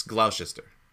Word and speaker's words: Gloucester: It's Gloucester. Like, they say it in every interview. I think the G is silent.